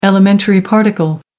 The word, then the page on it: elementary particle: American English